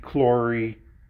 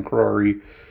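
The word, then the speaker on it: Krory: And so he joins the Black Order, and he meets Lena Lee, Lavi, Crory, I always say that wrong, Crory, Kanda, and so many other characters.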